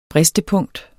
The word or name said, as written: bristepunkt